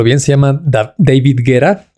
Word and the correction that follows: Guetta: o David Guerra, Guetta.